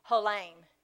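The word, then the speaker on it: Holam (חלום): Uh, the Hebrew word is, uh, let's see, how do you say it? I forgot. Hang on, let me look because I don't want to say it wrong. Holam, To dream means holam. or, or dreaming can mean holam.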